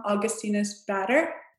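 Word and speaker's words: Augustinus Bader: I guess I would have to say and correct me if I say this wrong, Augustinus Batter. – Yes. Did I say it wrong? No, that's perfect.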